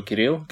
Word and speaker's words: Кирилл: Ну, во-первых, в, в английском нету буквы И, у mm-hmm. них есть И, и у них нету Р, у них там Р, и, короче, Кирилл или что-то такое. И это если они еще правильно поставят ударение, обычно получается Кирилл, ну, короче, такой вот ад.